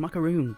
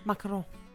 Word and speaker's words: macaron: It's a macaroon, babe. Come on now. Come on now. – I don't know. Because it's French. Macaron.